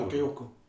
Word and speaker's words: Bakayoko: Bakayoko.